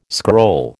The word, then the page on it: scroll: American English